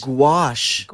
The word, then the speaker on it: gouache: Gouache.